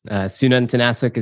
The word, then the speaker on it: Sununtnasuk: Sununtnasuk is how you pronounce the last name, but Damon is totally fine.